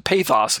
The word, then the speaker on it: pathos: It is pathos. Give me a second here. Pathos. Ah, okay. It's pathos. I mispronounced it last time. P A T H O S. Pathos.